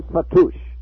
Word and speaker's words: fattoush: And then fattoush, which is cucumber and tomato salad.